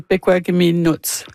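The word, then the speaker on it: Pekuakamiulnuatsh: Est-ce que je le prononce bien, le nom de la nation? Je vous avoue que c'était la première fois que je voyais Pequaka-Miulnout. – Oui, c'est Pequaka-Miulnout.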